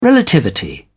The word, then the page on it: relativity: British English